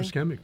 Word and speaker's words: ischemic: As explained in a medical paper titled Neuroprotective Mechanisms of Taurine Against Ischemic Stroke. Did I pronounce that correctly? – Ischemic, right.